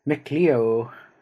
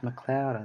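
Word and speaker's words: MacLeod: Or the local real estate sign, it says McLeo. It actually pronounced McLeod, I think. But it did come in as capital M-A-C, capital L-E-O-D-S.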